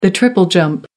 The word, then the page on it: triple jump: American English